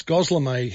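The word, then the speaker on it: Gözleme: Is it Goslamay, Goslemy? How do you say it? It's Goslamay, Hannah tells me.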